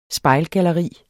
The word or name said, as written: spejlgalleri